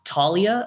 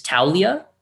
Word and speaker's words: Taulia: It's not Talia, apparently, it's Taulia which is you know something good to know. Like ow is not like when you hurt yourself is how he explained it to me.